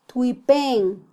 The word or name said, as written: twipen